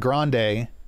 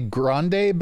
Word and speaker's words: Grande: and they're behind Ariana Grande, Ariana Grande. I keep wanting to say Grande because it's the Spanish pronunciation, but I guess it's actually Italian, so it's Grand.